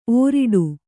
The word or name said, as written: ಓರಿಡು